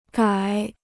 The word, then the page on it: 改: gǎi